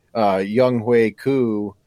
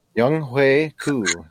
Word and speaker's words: Younghoe Koo: Younghoe Koo, yes, I know how to say his name. I wonder if Jay even knows. Younghoe Koo, that's how you say his name.